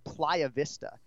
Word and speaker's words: Playa Vista: So today, for the first time in 131 episodes, Ken Flo is live in studio in Playa Vista Sounds fancy.